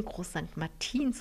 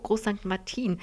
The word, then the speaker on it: Groß St. Martin: Sie sagen Groß St. Martin und ich musste mir das mühsam angewöhnen, Groß St. Martin zu sagen, mit der Betonung auf dem I.